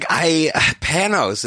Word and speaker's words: Panos: Like, Panos, is that how you say his name? – Yeah.